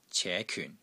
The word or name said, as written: CE2 KYUN4